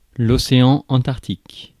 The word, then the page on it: océan Antarctique: France; /ɔ.se.ɑ̃ ɑ̃.taʁk.tik/; French